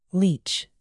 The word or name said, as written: leach